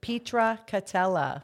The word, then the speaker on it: Pietracatella: Now, I've known Sally for 10 years, and she told me right before we came on air that I'm pronouncing her name incorrectly. – Yes, you are. 10 years. Pietracatella. – Pietracatella. – Bingo.